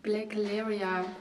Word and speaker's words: Blacleria: Black Laria wird äh, ausgesprochen ähm, und buchstabiert ist es dann B-L-A-C-L-E-R-I-A.